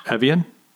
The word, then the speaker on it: Evian: Evian? – Yeah.